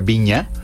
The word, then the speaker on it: Vigna: Se llama Flor Viña. Se escribe Vigna, pero Vigna. Se pronuncia Viña. – Ah. – Como Viña del Mar.